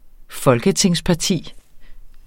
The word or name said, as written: folketingsparti